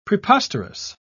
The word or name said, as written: preposterous